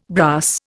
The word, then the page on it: brass: Indian